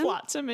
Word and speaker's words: Flotsam: Flotsam and Jetsam.